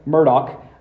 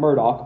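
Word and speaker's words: Murdaugh: He pronounces his name Alec Murdoch, although it should be pronounced Alex Murdo, which is like the first sign this is a really terrible human. I'm just kidding. But it's Alec Murdoch is how he pronounces it.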